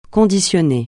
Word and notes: conditionné: francais

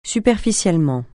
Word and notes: superficiellement: francais

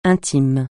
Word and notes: intime: francais

